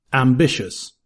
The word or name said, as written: ambitious